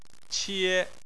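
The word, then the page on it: qie: tone 1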